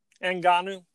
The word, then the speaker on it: Ngannou: Ngannou. – How do you say it again? – Ngannou.